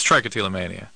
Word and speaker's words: trichotillomania: It's trichotillomania. – Oh, okay. Sorry, I mispronounced it.